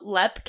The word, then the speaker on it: Leap: We're doing a group paranormal about Lepp Castle. – Uh-huh. – It's spelled Leap, but we're going to go with Lepp. – That's what we've heard the locals say there, so we're gonna go with that.